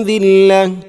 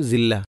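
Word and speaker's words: ദില്ല (ذلة): തർഹഖുഹും സില്ല, ദില്ലക്ക് പകരം പലപ്പോഴും സില്ല. ഇതൊക്കെ ശ്രദ്ധിക്കണം. തർഹഖുഹും ദില്ല.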